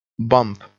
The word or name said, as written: bump